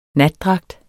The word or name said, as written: natdragt